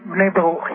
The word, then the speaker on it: B'nai Baruch: Okay, I would like to start by introducing the Association of B'nai Baruch. Am I pronouncing it correctly? – B'nai Baruch, yes.